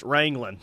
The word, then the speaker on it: Ringling: Uh, as someone from down that way, Ringling is pronounced Wrangling.